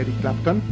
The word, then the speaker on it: Eric Clapton: en toma uno después de haber escuchado I Get Lost a cargo de Eric Clapton.